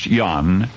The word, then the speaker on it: Jan: Now, you remember the career created by charisma and sheer determination as that of John, pronounced Jan, Ignaz Jan Paderewski.